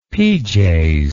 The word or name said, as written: pjs